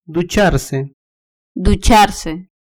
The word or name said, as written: ducharse